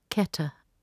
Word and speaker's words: Kether: It is spelt K-E-T-H-E-R, but it is pronounced Keter. Inside the second circle, write Tiferet, spelt T-I-P-H-E-R-E-T-H, but it is pronounced Tiferet.